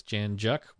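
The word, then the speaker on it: Jan Juc: All right, next we have an email from Darcy in Janjuk, Australia, and we got a proper pronunciation for that is Janjuk, which rhymes with duck.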